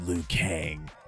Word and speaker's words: Liu Kang: Discover a reborn Mortal Kombat universe created by the fire god Liu Kang.